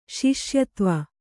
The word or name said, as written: ಶಿಷ್ಯತ್ವ